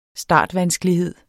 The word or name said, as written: startvanskelighed